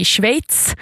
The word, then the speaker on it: Schweiz: Årets turnering spelas borta i Schweiz. Nu uttalar jag det rätt. Det är sjukt svårt. Jag lär mig aldrig hur man ska uttala Schweiz.